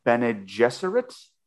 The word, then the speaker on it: Bene Gesserit: She also discovers a note from another Bene Gesserit.